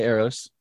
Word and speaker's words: Eros: Is that, I'm saying that right? – Eros. I say Eros. – Okay. – You can say Eros.